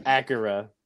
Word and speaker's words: Akira: It's pronounced Akira. Akira. No, yes, it is. – It's a car, Acura. – Akira. – It's Akira. Akira, isn't it? – Akira.